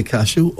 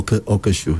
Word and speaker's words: cashew: Okay, you can say cashew or cashew.